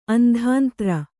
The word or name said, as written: ಅಂಧಾಂತ್ರ